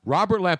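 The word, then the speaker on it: Robert Lamm: Robert Lamb. – And it's spelled L-A-M-M. – Right, but it's still Robert Lamb. – Yes. – Robert Lamb.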